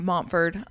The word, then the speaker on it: Montford: It's tucked away on Mumford. I always say that wrong. Montford.